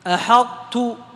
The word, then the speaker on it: أحطت: طيب هنا نبدأ أثناء النطق بطاء أحط وننتهي بتاء أحطت هكذا، ونركز على ترقيق التاء أيضا، نركز على ترقيق التاء لئلا تجرها الطاء التي قبلها. فما نقول أحطت، أحطت تفخيم خطأ،